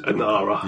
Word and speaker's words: Inara: Something about, was it Inanna's? What do you pronounce it? – Inara.